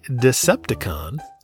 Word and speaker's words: Decepticon: AC Decepticon. Yeah, I got that one right. I didn't mispronounce it this time. Let's go, baby. – Yeah, I mean, it does lend itself to saying deception, and I even know what it is.